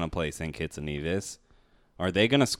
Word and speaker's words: Nevis: Nevis, I learned last night, is how you pronounce it. – Nevis.